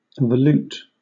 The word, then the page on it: volute: British English